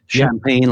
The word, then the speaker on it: Champagne: Well, Denis Champagne, as you know, is the name is spelled D-E-N-I-S. Champagne, like the bubbly stuff that you like to drink.